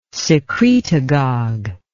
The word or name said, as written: secretagog